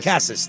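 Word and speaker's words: Casas: Cassis, thank you.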